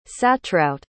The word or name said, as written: satruijt